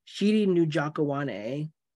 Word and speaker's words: Chidi Njokuani: Chidi Njokuani.